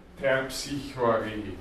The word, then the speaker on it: Terpsichore: Ähm, und Jota, dann Chi, auch das eine Besonderheit, CH, ja, also nicht x, sondern ch, wir haben es besprochen, Omikron, Rho und ein langes E. So. Terpsichore, Terpsichore.